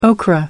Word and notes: okra: American English

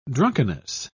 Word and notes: drunkenness: British English

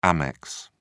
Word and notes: amex: British English

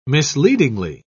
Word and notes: misleadingly: British English

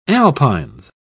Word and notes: alpines: British English

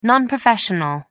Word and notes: non professional: American English

